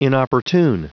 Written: inopportune

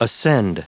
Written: ascend

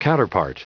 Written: counterpart

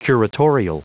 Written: curatorial

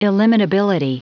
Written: illimitability

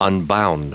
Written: unbound

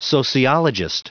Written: sociologist

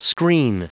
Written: screen

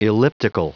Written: elliptical

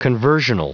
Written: conversional

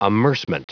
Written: amercement

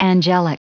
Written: angelic

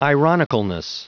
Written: ironicalness

